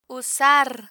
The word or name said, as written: usar